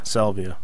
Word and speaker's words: Salvia: how do you say it? – Salvia? – Salvia. Yeah, which is, I'd never heard of this particular drug, and I've done some research on it.